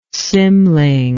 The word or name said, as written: cymling